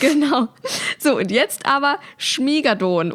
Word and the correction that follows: Schmigadoon: Es klingt wie etwas aus einem, ich weiß nicht, Drachenuniversum. – Es heißt Schmigadoon. – Ah, Schmigadoon.